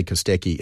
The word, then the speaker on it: Kostecki: So Brody Kostecki, which we say Kostecki, he's on poll at the moment.